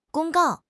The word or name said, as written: 公告